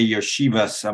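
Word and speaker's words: Iosivas: And then Princeton, I'll see if I can pronounce it right, Andre Yoshivas. I'm curious what you think of him. – First of all, you nailed the pronunciation there. So nicely done on that.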